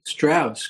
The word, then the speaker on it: Straus: Straus, correct.